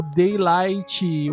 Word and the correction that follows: Dying Light: O Dying Light. Melhor dizendo, a pronúncia nórdica correta.